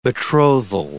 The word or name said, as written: betrothal